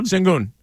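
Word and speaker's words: Şengün: How do you say his name? The guy with the Rockets has been nasty. – Sengun. – Yeah.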